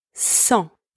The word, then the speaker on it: sang: Second word, sang, spelled S-A-N-G, means blood. In that word, the letter S is also pronounced S. The letters A-N together are pronounced en, and the final consonant G is silent. Third one, sang, spelled S-A-N-S, means without.